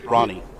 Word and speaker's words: Rahne: But their coach, Rainey, did we pronounce that right? Coach Rainey? – Coach Rainey. – Yeah. – Ronnie. – Ronnie, that's right, like the Rock.